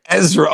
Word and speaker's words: Ezra: Did you say Urza Bridger? – Ezra. Oh my god, what is it with your pronunciations? – I've never watched Rebels.